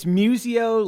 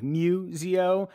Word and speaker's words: Muzio: It's Muzio, like if a cat is making a sound, Muzio.